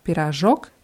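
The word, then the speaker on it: пирожок: Also da verschwindet auch dieses O vor dem letzten K. Pirajok, Pirajki.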